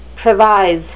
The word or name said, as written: previse